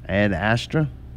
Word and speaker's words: Ad Astra: Ad Astra? – Yep. – Yeah. But it's all good. – You think this is good? You're saying yeah. – No, we're saying that's how you say it.